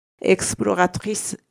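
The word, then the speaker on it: exploratrice: あの形が違って、最後の手、あの手の後はですね、う、ゆ、えはの部分が、え、い、す、うになって、エクスプロラトリス、エクスプラ、言いにくいんですよね。エクスプロラトリスになります。で、ちょっと発音も変わるということですね。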